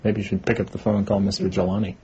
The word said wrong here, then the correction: Jalili: Jalili. – Thank you. – Yeah. Pick up the phone, call Mr. Jalili.